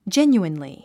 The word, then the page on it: genuinely: dʒénjuinli; ジェニュインリィ